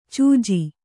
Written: ಚೆಜ್ಜ

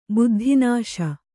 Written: ಬುದ್ಧಿನಾಶ

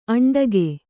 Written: ಅಂಟಿಕ್ಕು

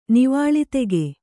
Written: ನೀವಾಳಿತೆಗೆ

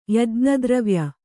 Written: ಯಜ್ಞದ್ರವ್ಯ

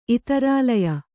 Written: ಇತರಾಲಯ

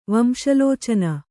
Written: ವಂಶಲೋಚನ

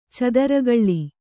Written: ಚಿತ್ರಕಲೆ

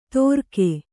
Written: ತೋರ್ಕೆ

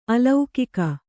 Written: ಅಲೌಕಿಕ

